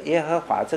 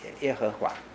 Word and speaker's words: 耶和华: 好，那么以后以色列人读这个耶和华这个名的时候，不敢读的，不敢读，因为他们怕妄称神的名，犯了十诫，所以他们就把那个主，主的发音拿来用，所以我们就改把它叫做，只有我忘记了，只有没有母音，所以我们的发音就变成耶和华。